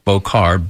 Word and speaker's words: Beaucar: That's spelled K-E-L-L-E-Y, Bokar, B-E-A-U-C-A-R, Vlahos. And that's V, like Victor, L-A-H-O-S.